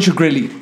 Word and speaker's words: Shkreli: Y Martin Shkreli, me parece que se pronuncia así.